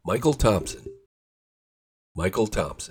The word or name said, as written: Mychal Thompson